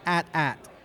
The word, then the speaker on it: AT-AT: They get promos, and they get press releases from Star Wars, and it's AT-AT. – Yeah! – Oh, snap! – Yeah, as in rhymes with hat is the way the press release says.